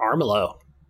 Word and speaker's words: Armello: You do get, how do you say it, Armello? A-R-M-E-L-L-O? Or Armello? – Armello.